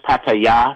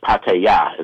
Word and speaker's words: Pattaya: It's pronounced Pattaya, if you're going to say it the right way. Thais enunciate the last syllable, not the first syllable. – Uh-huh. – So it's called Pattaya is how it's pronounced.